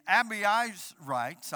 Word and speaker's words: Abiezrites: Word of God says he blew a trumpet, summoning the Abiezrites. I had to say that right. I looked that up in Hebrew.